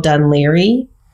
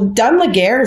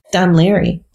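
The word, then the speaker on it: Dún Laoghaire: Yeah, there's an area near us called Dunleary. – Uh-huh. – So I've been looking for it because I was like, well, Dunlaguer is down the street. – And that's how they spell it. – Dunleary.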